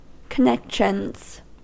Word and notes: connections: American English

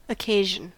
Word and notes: occasion: American English

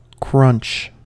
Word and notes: crunch: American English

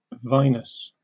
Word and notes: vinous: British English

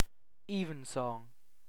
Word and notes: evensong: British English

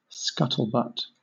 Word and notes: scuttlebutt: British English

